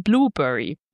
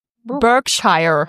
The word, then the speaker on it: Berkshire: Eigentlich lebt Papagei Rocco in einem Vogelreservat, nämlich dem National Animal Welfare Trust Sanctuary in Blueberry. – Berkshire.